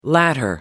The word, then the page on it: latter: American English